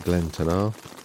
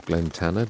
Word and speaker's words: Glen Tanar: It's 6 o'clock now, and we're at the Shield of Glen Tanar, or Glen Tanner, depending how you want to pronounce it, which is at the base of Mount Keen for approach tomorrow.